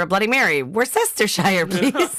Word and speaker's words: Worcestershire: And this is the most accepted pronunciation in American English is Worcestershire. The key is to drop the first Y and soften the C-E, and I've always been able to say that one.